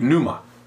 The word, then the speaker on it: pneuma: That's how to say it in the Greek, pneuma.